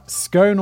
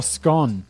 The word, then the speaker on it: Scone: Scone or Scone. I think that there are two kind of accepted pronunciations of Scone in the UK. I feel like Scone is a slightly posher way of saying it.